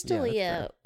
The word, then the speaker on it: D'Elia: Is that really how you say his name? I'm like questioning it. – Yeah, Chris D'Elia, I swear to God.